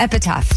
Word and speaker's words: epitaph: An epitaph? Epitaph? Oh. Epitaph. – So it's like when someone dies. That's what that is. – Is that how you say it? – Yeah, sure. – Yeah, cool. Epitaph.